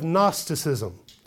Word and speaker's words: Gnosticism: Gnosticism. And if you haven't come across that word before in your reading, that starts with a G. It's a silent G. It's G-N-O-S-T-I-C-I-S-M.